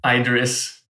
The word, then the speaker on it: IDRiss: I'm not sure whether it should be called IDRiss or IDRiss or like what's the proper pronunciation. – IDRiss.